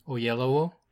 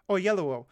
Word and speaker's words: Oyelowo: Oyelowo. – Oyelowo.